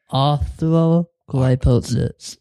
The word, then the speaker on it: arthrogryposis: So no, how do I say it correctly? – It's called arthrogryposis.